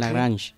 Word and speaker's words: Lagrange: Lagrange.